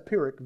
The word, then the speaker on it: Pyrrhic: Friends, holding on to the law after you come to Christ, listen to me very carefully, is a Pyrrhic victory. Now, there's a word you don't use very often. Let me spell it for you. P-Y-R-R-H-I-C, Pyrrhic victory.